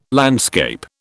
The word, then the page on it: landscape: British English